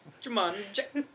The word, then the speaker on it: Jumanji: Jumanji. That's how you pronounce it.